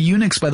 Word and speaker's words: Unix: By the way, Unix, was originally spelled UNICS, but because we pronounce that Unix, they just replaced the CS with an X eventually. So that's why it's spelled UNIX.